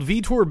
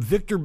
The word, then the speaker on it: Vitor: where he called Vitor Belfort a boxer, and he mispronounced his name, calling him Victor Belfort, which sounds like a character from Castlevania.